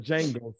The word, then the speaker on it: Django: Saying the Django suite. – Mm-hmm. Yep. What is that nigga doing on that neck? – The D is silent.